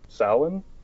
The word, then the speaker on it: Samhain: Samhain? – Yes.